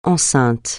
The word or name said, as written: enceinte